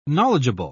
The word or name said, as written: knowledgeable